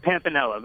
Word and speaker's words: Pampinella: Pampinella, but it's okay.